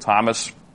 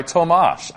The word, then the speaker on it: Tomas: And by the way, I got to the bottom of the pronunciation business with Thomas, the artist formerly known as Thomas Wolde Tensai. He says he prefers to go by Tomas.